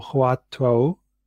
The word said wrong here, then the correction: Huatou: My apologies for mispronunciation. It's Huatou, where there is a strong emphasis on the cultivation of doubt.